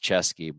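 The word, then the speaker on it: Ceschi: I'm gonna spell it because it's not C H. It's C E S C H I. Pronounced Chesky, but he's saying it wrong.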